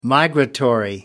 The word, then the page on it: migratory: British English